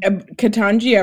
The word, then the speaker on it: Ketanji: Ketanji, I've heard it said. I've listened to some her recordings of her saying her own name, Ketanji.